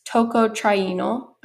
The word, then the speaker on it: tocotrienol: So you did mention this compound, this tocotrienol.